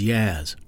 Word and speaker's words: Yaz: He's known to his colleagues and students as Yaz, rhymes with jazz.